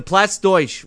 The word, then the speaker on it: Platz Deutsch: Now, last week, Sean O'Brien shows up to the Platz Deutsch restaurant. That's how it's pronounced, by the way. – Douche. – Just so you know, Platz Deutsch.